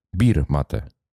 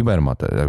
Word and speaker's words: Bermate: No ale słuchaj, Bermate nazywa się Bermate po to, żeby w Polsce ludzie oczywiście to błędnie wymawiali Birmate. To jakby... – A jak wymawiają? – Tak, no, wymawiają Birmatę. – Birmatę. – Tak jak założyliśmy. Okay. Ale się mówi Bermatę. Znaczy prawidłowo powinno być Bermatę.